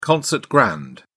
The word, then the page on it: concert grand: British English